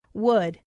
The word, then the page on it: would: female